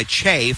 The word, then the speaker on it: Kyei: Second shot by Bass, and that one is rims out, no good, and the rebound pulled down by Che for the Ducks. Last name is spelled K-Y-E-I, but pronounced Che.